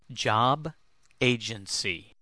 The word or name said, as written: job agency